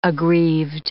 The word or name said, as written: aggrieved